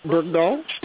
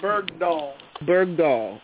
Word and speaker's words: Bergdahl: Bergdahl. Yeah, Bergdahl.